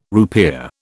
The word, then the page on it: rupiah: British English